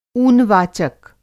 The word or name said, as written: ऊनवाचक